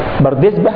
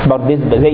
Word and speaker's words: بردزبة: هو الإمام أبو عبد الله محمد ابن إسماعيل ابن إبراهيم ابن المغيرة ابن بردزبة الجعفي. بردزبة هكذا تنطق بالهاء الساكنة وصلا ووقفا، بردزبة زي ابن وارة وابن مندة وابن ماجة، هي كلمة أسماء أعجمية هكذا نطقها أصحابها.